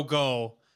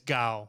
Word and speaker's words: Gao: Because I said Joe Go on the video, and it's Gao. – Gao. – Yeah. Okay. Like ka-chow.